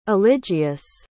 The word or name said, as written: eligius